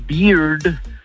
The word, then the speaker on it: beard: Porque con, o sea, la barba se pronuncia beard, el oso se pronuncia bear, ¿eh? Y la cerveza se pronuncia beer. – ¿Eh? – Pero con R al final.